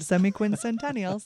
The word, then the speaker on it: semiquincentennial: Speaking of the history of America and the 250th anniversary, the semiquincentennial. – Is that how it's said? – Semiquincentennial.